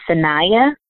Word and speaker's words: Saniyyah: So my first name is spelled S-A-N-I-Y-Y-A-H. And so my Instagram is Sanaya _ real estate.